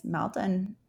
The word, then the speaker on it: Maldon: There's, I love as a brand of a Celtic salt that I'm not sure if I say it right, but Maldron. It's in our Amazon store. – Link to it for sure. I think it's Maldon, M-A-L-D-O-N.